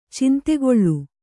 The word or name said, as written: ಚಂದಕಲ